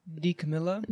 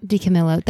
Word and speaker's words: DiCamillo: How do I say that? – DiCamillo? – DiCamillo. That's so embarrassing.